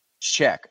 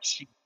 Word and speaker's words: Cheick: One guy that I actually, or two guys I should say, that you brought up that I'm interested to see is how do you say Diallo's first name? – Cheick. – Okay. Yeah, so I've always been calling him like Cheek or something like that.